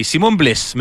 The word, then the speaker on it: Blaise: La verdad es que el apellido es de origen francés y la pronunciación es Blaise. – Ok, Simón Blaise, me parece excelente.